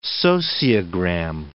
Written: sociogram

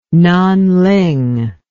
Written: Nanling

